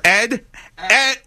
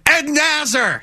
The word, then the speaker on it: Asner: Ed Asner. Ed Nasser.